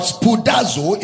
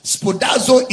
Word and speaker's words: spoudazo: It is imperative to note that Brother Paul used the same word spoudazo. If you are making notes, spoudazo is spelled as S P O U D A Z O.